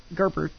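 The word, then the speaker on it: Gerbera: And these are Gerber daisies, if anyone cares. And there's some beautiful pink roses laying across the station. Thank you for pronouncing that flower correctly.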